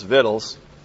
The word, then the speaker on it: victuals: Of course, that word properly pronounced in the vernacular Florida redneck is Vittles.